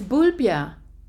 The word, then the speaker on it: Bulbjerg: How do you pronounce it? – It's Bulbja.